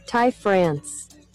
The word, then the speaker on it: Ty France: Ty France.